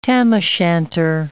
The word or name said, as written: tam-o'-shanter